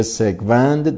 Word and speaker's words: سگوند: یک روز پس از مرگ مشکوک منصور سگوند، البته تلفظ درستش سین با فتحه هست، به هر روی منصور سگوند دانشجوی ساکن آبدانان در پی قطع اعتراضی همکاری او با نیروی انتظامی موجب واکنش مقامات دادگستری استان ایلام شد.